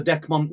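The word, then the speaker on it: Dechmont: I don't know enough about the Decmont Woods. I'm glad you could pronounce that because I'd have said Dermot or something silly, but I don't know enough about it.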